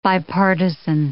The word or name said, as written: bipartisan